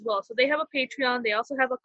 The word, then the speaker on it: Patreon: They also have a Patreon. Am I saying it right? I always say Parthenon. I do not know why, but yes, they have a Patreon, and you could also tip like a few dollars here and there.